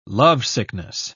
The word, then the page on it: lovesickness: British English